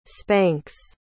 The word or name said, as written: spanks